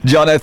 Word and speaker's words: Joneaux: I didn't do myself any favors with my name, but it's spelled J-O-N-E-A-U-X, and you just pronounce it Jonner, but if you search Songs South Africans Forgot About, I should come up. But yeah, thank you. – Jonner, thank you for your time this afternoon and for joining us on the Subaru Afternoon Drive on Helderberg FM.